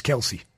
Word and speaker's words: Kelce: K-E-L-C-E, it's Kelsey. – It is Kelsey.